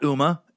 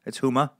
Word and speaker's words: Huma: Not Uma, as some people in the public eye like to say. That is not how you say her name. It's Huma, not Uma.